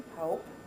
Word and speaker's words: Hope: My name is Marion Elizabeth Lorman Hope. And first name is Marion, M-A-R-I-O-N. Last name, L-A-R-M-O-N-D, for Lorman Hope, H-O-P-E.